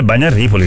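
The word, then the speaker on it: Bagno a Ripoli: Quello è Bagnaripoli, il comune.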